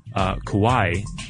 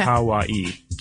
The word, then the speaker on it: kawaii: She says, "Hey guys, I just wanted to let you know that in Japanese, kawaii, K-A-W-A-I-I, well, that's the, you know, the English version of the kanji, cute is pronounced ka, like the kaing of a crow. So ka. Ka, like the cawing of a crow. Wa is in water, and I as in the letter E. So kawaii, like Hawaii.